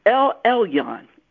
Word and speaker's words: El Elyon: That word, the Hebrew word, El Elyon. El Elyon, that's spelled capital E-L, then the next word is E-L-Y-O-N, El Elyon.